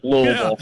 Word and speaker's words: Louisville: If you're from here, though, you pretty much drop the I and the S part of it, though, in the Louis. So it's like Louisville.